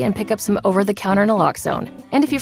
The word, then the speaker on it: naloxone: For the record, it's naloxone. Nuh, like, is naloxone addictive? Nuh-uh. Lox, like naloxone keeps humans safe, like locks keep bikes safe.